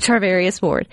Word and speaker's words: Charvarius Ward: Charvarius Ward.